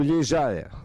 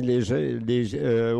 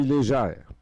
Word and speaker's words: Légère: Et maintenant, nous continuons à CKUT Montréal, avec Maria Léger ou Légère. Ça dépend comment on le prononce. Ici, au Canada, on dit Léger. Au Québec, on dit Léger. Et en Acadie, on dit Légère. Et Lenny Gallant et Glamour Puss, P-U-S-S, P-U-S-S, Glamour Puss.